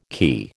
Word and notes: quay: American English

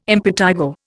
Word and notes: impetigo: Indian

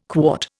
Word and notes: quart: Indian

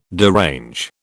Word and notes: derange: British English

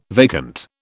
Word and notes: vacant: British English

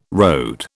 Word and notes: RD: British English

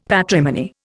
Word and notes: patrimony: Indian

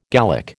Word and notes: Gallic: American English

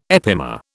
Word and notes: epimer: British English

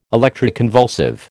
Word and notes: electroconvulsive: American English

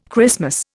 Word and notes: Christmas: Indian

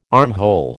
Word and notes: armhole: American English

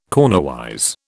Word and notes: cornerwise: British English

